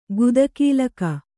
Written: ಗುದಕೀಲಕ

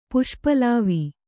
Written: ಪುಷ್ಪಲಾವ

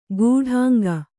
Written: ಗೂಢಾಂಗ

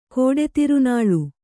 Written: ಕೋಡೆತಿರುನಾಳು

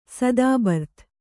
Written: ಸದಾಬರ್ತ್

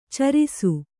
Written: ಚಾರಿಸು

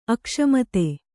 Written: ಅಕಸ್ಮಾತ್ತು

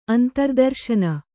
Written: ಅಂತರ್ದರ್ಶನ

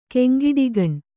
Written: ಕೆಂಗಿಡಿಗಣ್

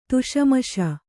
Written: ಧುಸುಮುಸಿ

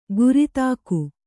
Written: ಗುರಿತಾಕು

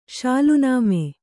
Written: ಶಾಲುನಾಮೆ

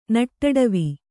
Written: ನಟ್ಟಡವಿ

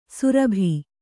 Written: ಸುರಭಿ

